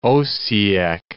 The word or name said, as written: Osijek